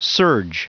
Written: surge

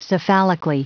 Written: cephalically

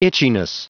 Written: itchiness

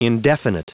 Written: indefinite

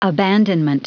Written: abandonment

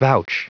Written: vouch

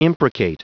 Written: imprecate

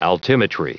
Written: altimetry

